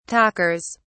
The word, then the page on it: talkers: British English